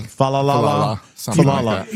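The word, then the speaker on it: Faalele: Falalele. – You deck the halls with boughs of holly. – Yeah. Mm-hmm. – Fa-la-la-la-la-la-la-la. – They're coming to Josh's town, Jacksonville, Florida, for a late round 2027 draft pick.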